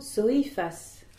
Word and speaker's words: soifas: Il y a effectivement trois syllabes dans ce mot. Soifas, et tu as accentué l'avant-dernière syllabe qui n'est constituée que du i.